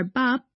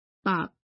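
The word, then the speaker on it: bap (밥): The examples in Korean here are bap, which means rice, and moon, which means door. So those are syllables and complete words too. 1. Consonant plus vertical vowel plus final consonant, bop.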